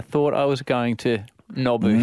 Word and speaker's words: Nobu: Nobu, yes.